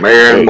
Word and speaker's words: Maren: Is it M-A-R-E-N? – Yeah. – Okay. – Let me double check. – I can help with this pan thing. – M-A-R-E-N. Marion Morris. Is that how you say it? – I think so, yes.